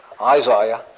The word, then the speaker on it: Isaiah: Isaiah, is that the correct pronunciation? That's right. She'd be right. Isaiah.